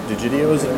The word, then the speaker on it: Digidio's: We're at Glockenspiel, which is actually right down the street from Digidio's. We decided that's like the actual pronunciation.